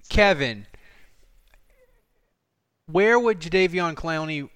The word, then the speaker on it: Jadeveon Clowney: You said Jadeveon Clowney correctly.